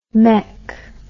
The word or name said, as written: maik